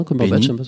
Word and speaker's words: Cumberbatch: Tu parlais de Cumberbatch et de quel que soit, tu le prononces comment, Cumberbatch. Bah, tu vois, Cumberbatch, ça me semble bien comme ça.